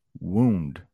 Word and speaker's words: wound: Past tense is wound. Wound. Wound. That's how you pronounce that. Wound. I hope that is clear. The past tense of wind. This is wound.